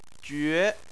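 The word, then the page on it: jue: tone 2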